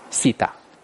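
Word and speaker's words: theta: 这个怎么念？西达，啊，因为我们中文那个很难发，呵呵，我们讲了 three 啊，都在讲 three 都变成 s 了，好了，随顺一下叫西达。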